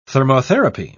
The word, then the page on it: thermotherapy: British English